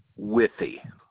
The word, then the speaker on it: Withey: Dale Withey. W-I-T-H-E-Y. – And that's Dale, D-A-L-E.